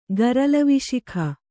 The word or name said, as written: ಗರಲವಿಶಿಖ